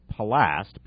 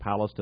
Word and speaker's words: Palast: This is by BBC journalist Greg Palast, P-A-L-A-S-T, or Palast, I've heard it pronounced.